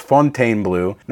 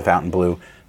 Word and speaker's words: Fontainebleau: I get so many comments of people trashing us and saying, no, it's Fontainebleau. Now, that's the French pronunciation of it, and it's spelled in the French way, but it is called Fontainebleau.